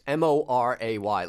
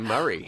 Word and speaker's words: Moray: All right, you mentioned this Jim Murray piece, Moray. It looks like Moray. – Moray. – But when he was here, he pronounced his name Murray.